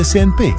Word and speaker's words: CNP: Si legge SNP, si scrive CNP.